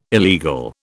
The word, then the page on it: illegal: British English